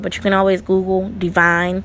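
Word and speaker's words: D'Vine: It's spelled D apostrophe V-I-N-E, so it's Divine, which is located in South Beach.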